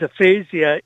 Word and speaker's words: aphasia: You wanted to also highlight aphasia, or am I pronouncing that properly? – Aphasia, yes.